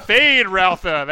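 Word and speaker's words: Feyd-Rautha: I would say that perhaps the most memorable performance in the entire movie is Sting, as you pronounce this for me, Feyd Rautha? – Yeah. Feyd Rautha, there it is.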